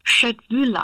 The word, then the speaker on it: Köttbullar: Die werden nicht so ausgesprochen, oder? – Zumindest hier in Deutschland kriegt man kein Gericht, wenn man nicht mit viel Gusto Köttbühler bestellt. – Ja. – Genau, weil in Schweden guckt man einen dann doppelt verwirrt an. Die gab es bei mir relativ häufig zum Mittagessen. Hatten wir eine ganz fantastische Mensa, da gab es die. – Nochmal, wie werden die ausgesprochen? – Schöttbühler.